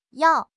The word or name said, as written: yao4